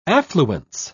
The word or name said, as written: affluence